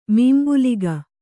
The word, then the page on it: ಮೀಂಬುಲಿಗ: mīmbuliga